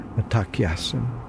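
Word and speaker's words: Mitákuye Oyás'iŋ: I believe it's pronounced Matakyasin.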